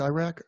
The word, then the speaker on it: Iraq: Is it Iraq? – Yes. Yeah. Yes.